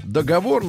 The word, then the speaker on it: договор: Вот, одобрила ударение в слове «договор» на первый слог.